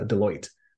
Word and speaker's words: Deloitte: It's not Doolittle, it's uh, Deloitte.